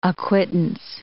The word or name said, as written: acquittance